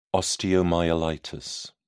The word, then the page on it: osteomyelitis: British English